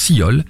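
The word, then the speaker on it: Silhol: ça se prononce Sillol,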